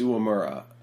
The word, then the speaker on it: Uemura: So Meltzer at one point said Uemura, and I was like, oh, I've been saying it wrong. But no, that's not the case. It is Uemura.